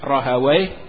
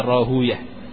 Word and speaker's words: Rahuyah: Ini ada yang baca Rahawai, ada yang baca Rahuyah. Tapi yang lebih tepat khususnya para ulama hadis membacanya Rahuyah.